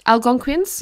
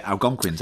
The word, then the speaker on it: Algonquians: What is that word? Algonquicans. – Algonquians? – Yeah, Algonquians, that's good.